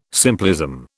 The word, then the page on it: simplism: British English